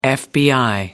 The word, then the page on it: fbi: American English